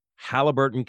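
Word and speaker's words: Haliburton: They are in Halliburton, Canada. Did I say that right? – Absolutely, you did.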